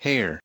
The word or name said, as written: hair